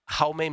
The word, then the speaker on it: Jaume: Jaume Munar.